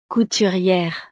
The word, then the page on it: couturiere: frances